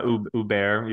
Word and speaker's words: Huppert: Huppert? Yeah, Huppert. You don't pronounce Huppert. The H, yeah.